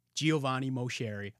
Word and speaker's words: Giovanni Moceri: I got my guy, Giovanni Moceri.